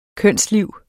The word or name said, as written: kønsliv